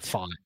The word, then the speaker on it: Pfaadt: Fought. Yeah, yeah. – P-F-A-A-D-T.